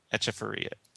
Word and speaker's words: Echevarria: it's definitely Etch Echevarria. – Right, not Eck. It's Eche.